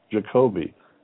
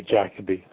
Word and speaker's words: Jacoby: Jacoby. – And how do you say it in England? – Over here, they say Jacoby.